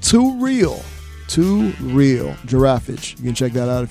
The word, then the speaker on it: Giraffage: So again, that's Giraffage. That is G-I-R-A-F-F-A-G-E.